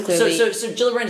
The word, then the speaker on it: Gillibrand: So was Kirsten Gillibrand from New York. – So Gillibrand is like, to correct your pronunciation,